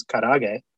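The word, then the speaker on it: karaage: So, you know, if we're going to Japan, I think it's karage is how you pronounce it.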